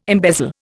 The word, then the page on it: embezzle: Indian